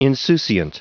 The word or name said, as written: insouciant